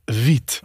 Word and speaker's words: Vite: I put it into the Google Translate and had them pronounce it, and it said vite. – Okay. – So I believe that's correct, although on the homepage it says it's pronounced V-I-T, which to me very much is vit. – Yeah, yeah. – And in my head forever, it was vite. Uh-huh. So all those are wrong. It's vite.